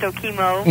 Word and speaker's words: Okemo: On the phone with me from Okemo or something like that there. – Yeah, I think it's pronounced Okemo.